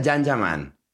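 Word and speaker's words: Can Yaman: Se escribe Kanyaman, se pronuncia Jan Jaman.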